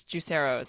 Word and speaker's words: Juicero: A lot of people were saying Juicero. – Juicero, yeah. – It is Juicero.